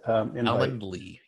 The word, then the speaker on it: Calendly: So you sent me Calendly, is that how you say it? – Calendly, yep.